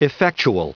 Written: effectual

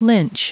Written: lynch

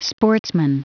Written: sportsman

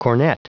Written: cornet

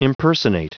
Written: impersonate

